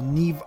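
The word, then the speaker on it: Niamh: Doveva, quindi Niam con l'H ed in verità si pronuncia Niv quindi è un po' particolare l'irlandese comunque Niv Algar eh,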